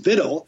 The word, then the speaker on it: victual: And we went back to the Oxford English Dictionary, and sure enough, it's pronounced victual. It comes from an old French word via the Latin, and it was pronounced victual.